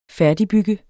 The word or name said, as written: færdigbygge